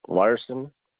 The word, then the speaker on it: Larson: J-E-R-R-Y. – And your last name? – Larson. L-A-R-S-O-N. – L-A-R. – S-O-N.